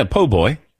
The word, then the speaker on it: Po' Boy: Did I say it right? – Yeah, Po' Boy.